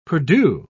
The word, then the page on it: perdue: British English